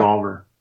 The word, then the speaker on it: EvolvR: EvolvR.